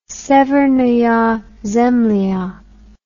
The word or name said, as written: Severnaya Zemlya